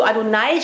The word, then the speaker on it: Adonai: Also lernen die, dass Adonai, dass Gott zu Adonai spricht in der Bibel. – And you can remember the word Adonai because it rhymes with El Shaddai.